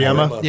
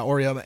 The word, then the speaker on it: Auriemma: Auriemma. – Yeah, Auriemma.